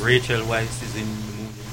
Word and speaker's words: Weisz: Again, it's Rachel Weisz. – Yeah, it is Weisz, by the way. – I am Jamaican. I'll pronounce it... – Rachel Weisz. – No, it's Weisz, motherfucker.